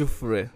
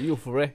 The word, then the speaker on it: Jufure: Eller nej, du sa det helt rätt. Jufure. – Jufure. – Jufure. Ja, oh, det är svenska. Mina svenska kompisar brukar säga Jufure. Ja, but nej, you gotta say it right.